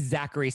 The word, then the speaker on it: Zachary: Well, my uh, so my name is Zachary, so it's spelled Z A C H A R Y, the, oh.